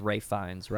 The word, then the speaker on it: Fiennes: Val Kilmer, who also voiced God in this movie, which I thought was which is very cool, and he and I believe it's pronounced Ralph Fiennes, right? Not Ralph.